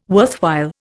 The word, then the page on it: worthwhile: Indian